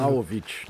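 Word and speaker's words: Vlahović: que com a iminente venda do atacante Vlaudic, Vlaudic, qual é a pronúncia? Sabe? Vlaudic, Vlaudic, deixa eu, deixa eu abrir o, o microfone. Vlaovic, na verdade. Como é? Vla, Vlaovic. Vlaovic.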